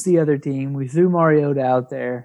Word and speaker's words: Mariota: Yeah, it's pronounced Mariota, but you can call him whatever you want.